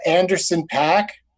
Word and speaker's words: Anderson .Paak: It's Anderson .Paak. – Uh-huh. – Am I even pronouncing it right? P-A-A. – You are. Yeah.